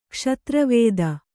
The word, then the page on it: ಕ್ಷತ್ರವೇದ: kṣatra vēda